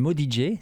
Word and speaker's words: Mundia: Alors déjà, je rectifie, on dit Modige, ça s'écrit Mundia, mais ça se dit Modige, et j'ai jamais compris pourquoi,